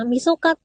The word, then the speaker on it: 晦日: っていうのも、まあ、晦日って、 30 日って書いて晦日って読みますけど、 12 月 31 日の大晦日って 31 日なんですよね。